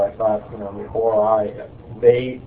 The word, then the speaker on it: Ori: And by the way, just you need to officially, you know, get out there and explain that it's Ori, not Ori and not Oreos. It's O-R-I, pronounced Ori.